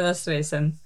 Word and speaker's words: Raztresen: My name is Sara Raztresen.